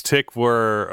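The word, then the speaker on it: Tykwer: Depending on how German you want to be, it's Tichver or Tichver. – I'd say Tichver.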